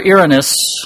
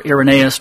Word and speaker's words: Irenaeus: The early church father Irenaeus, or Irenaeus, depending on how you want to pronounce it, was the first to use the word Gnostic.